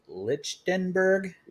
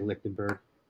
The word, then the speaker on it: Lichtenberg: The only thing we didn't touch on, and if I get skewered for pronunciation, because we kept going back and forth on the pronunciation here, Brady Lichtenberg. – Yeah, there's just no way that's what it's pronounced. I always say Lichtenberg. – Lichtenberg. – Let us know who's right. Like Hummer's team Lichtenberg, which I've never heard someone say a name that way before. – I'm just like phonetically going. It's silent. It's Lichtenberg.